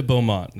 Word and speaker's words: Baudmont: So let's dive into Baudmont now.